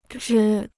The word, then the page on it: 支: zhī